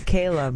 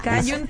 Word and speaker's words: Callum: Bueno, si se escribe C-A-L-L-U-M, Callum. – Callum Turner. – Es Callum, bro.